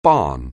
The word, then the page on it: barn: anglais